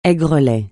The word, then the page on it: aigrelet: français